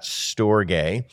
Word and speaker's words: storge: The next love is storge. And that is exactly how you pronounce that, storge, which is simply a familial love.